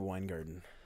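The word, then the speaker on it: VanWyngarden: I watched YouTube videos and they all pronounced it Wingarden.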